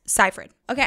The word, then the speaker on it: Seyfried: Seyfried. Okay, anyway.